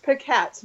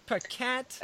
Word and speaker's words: Pacat: Please tell me I pronounced that right because I can't tell you how many times I, I butcher names on this show. it's pronounced Paquette, but um, Paquette.